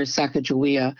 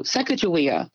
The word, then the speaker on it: Sacagawea: And they called her Sacagawea because they don't have a hard G in their language. So Sacagawea, either soft J or hard G, she comes when she's called.